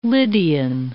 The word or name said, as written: Lydian